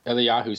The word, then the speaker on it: Eliyahu: And he had teamed up with another person who was really into it named, I can't pronounce, I think, is it Eliyahu Rips of the Hebrew University in Israel? – Eliyahu sounds, that sounds like an accurate pronunciation.